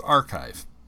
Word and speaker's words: arXiv: Now, in other sciences, there's something that is spelled ARXIV, but it's pronounced archive.